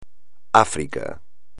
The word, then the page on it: África: espagnol